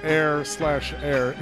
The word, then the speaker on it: H31R: We heard from Air, that is styled and spelled H31R, pronounced Air/Air, H-E-I-R/A-I-R.